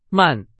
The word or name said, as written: 慢